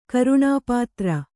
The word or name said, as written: ಕರ್ಣಪತ್ರ